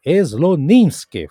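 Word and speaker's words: Slonimsky: e o próprio Slominsky, é, Sloninsky, falei errado aqui, ele disse que Ponce esteve muito perto do pensamento musical francês do século XX.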